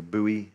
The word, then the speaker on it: buoy: Okay, if I'm in an ocean drowning, and someone throws me a life buoy, you know, or buoy, as Americans say. Otherwise, don't get that, but life buoy. We say buoy, B-U-O-Y, mm. But you actually say it like it's spelt, buoy, buoy, buoy. That's what it actually says, buoy.